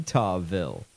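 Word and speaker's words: Eutawville: This is Richard Combs, former police chief of Utahville. But it's spelled E-U-T-A-W. – How infuriating. I mean, really, why spell it like that? – E-U-T-A-W, Utahville.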